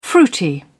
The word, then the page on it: fruity: British English